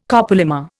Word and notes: copolymer: Indian

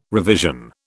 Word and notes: revision: British English

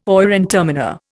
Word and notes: oyer and terminer: Indian

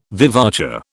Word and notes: vivace: British English

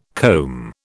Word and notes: comb: British English

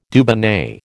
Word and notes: Dubonnet: American English

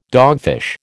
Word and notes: dogfish: American English

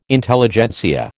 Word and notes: Intelligentsia: American English

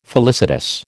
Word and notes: felicitous: American English

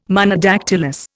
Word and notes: Monodactylous: Indian

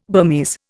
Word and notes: Burmese: Indian

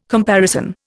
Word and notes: comparison: Indian

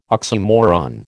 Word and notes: oxymoron: American English

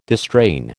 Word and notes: distrain: American English

